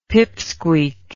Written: pipsqueak

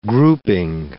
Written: grouping

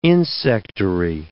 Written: insectary

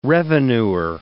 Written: revenuer

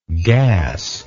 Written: gasless

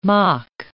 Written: moc

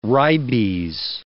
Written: ribes

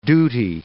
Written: duty

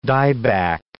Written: dieback

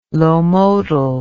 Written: lomotil

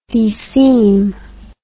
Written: deseam

